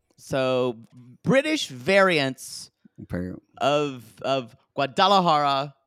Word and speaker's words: Guadalajara: So we have a couple of Guadalajara pronunciations. Guadalajara from Guadalajara. – Mm-hmm. – Guadalajara. Then one of her, either her sister or her mother or her friend says Guajaladara.